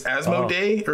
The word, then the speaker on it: Asmodee: It's French. So it's Asmodee, or at least that's how all of us Americans say it.